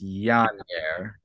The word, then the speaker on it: Yannier: This is a name I don't know how to pronounce. Yannier? – Yeah, that sounds good. – You want to take this one? – I think this is just Yannier.